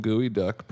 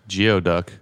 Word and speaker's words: geoduck: I think they had some gooey duck, perhaps. – Mm, which I once pronounced geoduck.